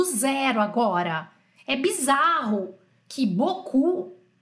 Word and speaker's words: beaucoup: Então aqui na verdade, a, a forma correta de escrever boku, que significa muito, é B-E-A-U C-O-U-P.